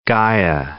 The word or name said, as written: Gaia